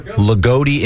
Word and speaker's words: Loogootee: L-O-O-G-O-O-T-E-E. – Ligoti? – Ligoti, Indiana.